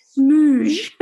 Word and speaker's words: Smug: Smooge, it's actually Isabella M. Smug, but a bit like Hyacinth Bucket. She pronounces it Smooge.